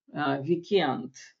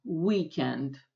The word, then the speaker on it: weekend: Ударение всегда падает на первую половинку, да, поэтому по-русски мы как раз чаще эти слова, даже те, которые взяли из английского, мы переиначиваем с ударением в конце. Мы говорим «мой бойфренд» по-русски, «weekend», а по-английски ударение должно быть в начале.